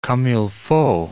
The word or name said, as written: comme il faut